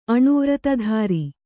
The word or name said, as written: ಅಣುವ್ರತಧಾರಿ